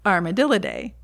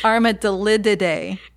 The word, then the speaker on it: Armadillidae: Armadillidae. – Say it again. – Armadillidae. – Armadillidae. – Cannot include their antennas in the ball. Armadillidae. – Armadillidae. – Armadillidae. – One less syllable.